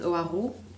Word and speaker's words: Hoareau: How you say it in French is Oahu, but like... obviously, if you're English, you don't say it like that.